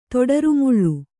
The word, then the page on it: ತೊಡರುಮುಳ್ಳು: kannada